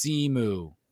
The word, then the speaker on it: Simu: And then he pronounced, and then he tweets out also for the 948 millionth time, it's pronounced Simu.